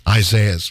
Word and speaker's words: Isaias: It's Isaias.